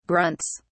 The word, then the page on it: grunts: British English